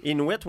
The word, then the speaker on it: Inuit: Un petit nanan pour la communauté Inuit. C'est-tu comme ça qu'on le prononce? – Inuit, oui, pour ce qui est là, de la transfuge GNP2, c'est clair.